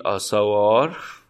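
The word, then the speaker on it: Assauer: باشه آره مرتزا این تلفظ اسمش آساوا اون آخرش مثل تلفظ شوا توی لحجه بریتیش تلفظ میشه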